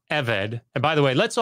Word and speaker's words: eved: So the word is eved, E V E D. The emphasis is on the first syllable.